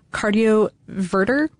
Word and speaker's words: cardioverter: There are also related implantable cardioverter. Did I say that right? – Yes.